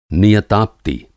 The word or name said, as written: नियताप्ति